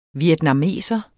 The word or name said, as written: vietnameser